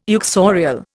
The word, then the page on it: uxorial: Indian